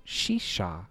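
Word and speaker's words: Tshetsha: And then by the Shisha Boys, I looked up the pronunciation, it's T-S-H-E-T-S-H-A Boys.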